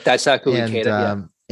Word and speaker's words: Daisaku Ikeda: You talk very heavily about, I'm going to pronounce his name, but your mentor, Daisaku Ikeda. Is that right? – Ikeda. Daisaku Ikeda.